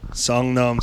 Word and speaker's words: Seongnam: Seongnam, South Korea.